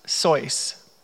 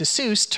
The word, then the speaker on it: Seuss: His name is actually pronounced Sois, like voice. He anglicized it to Seuss to rhyme with Mother Goose.